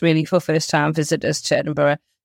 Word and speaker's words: Edinburgh: I will just start off actually by saying just a bit of a pet peeve for Doug and I's actually, is that it's pronounced Edinburgh, which I guess, obviously, learning how to pronounce different names, fair enough. But just to say that it's got an H at the end, so it's Edinburgh. It's not Edinburgh, so because we quite often see it without the H at the end.